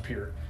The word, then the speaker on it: Peart: Or Peart. I think it's pronounced Peart.